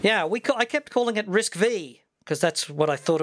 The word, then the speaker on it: RISC-V: He said, this falls in the picky category, but RISC-V is pronounced RISC-V. This is because it is the fifth reduced instruction set computer developed at Berkeley and Stanford universities, I assume, in the U.S.